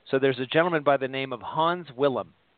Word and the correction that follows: Wilhelm: Wilhelm. I'll just kind of phonetically pronounce that, Hans Wilhelm.